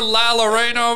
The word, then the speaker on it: La Llorona: No, La Llorona, I think you're saying. – Yeah, is that how you say it? See? – Llorona, because it's two L's.